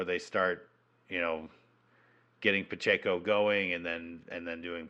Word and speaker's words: Pacheco: But Pacheco is, am I saying that right? Pacheco? – Yeah, I believe so.